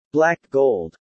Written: black gold

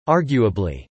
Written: arguably

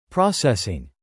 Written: processing

